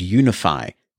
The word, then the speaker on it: UniFi: So you've got the more professional business-grade technology called Unify from Ubiquiti, and for those who've not seen that written down, it is spelled UNIFI,